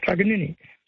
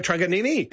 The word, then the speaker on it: Truganini: And as far as Truganina, it was always brought up as Truganini. – Oh, so Truganini. – It is Truganini.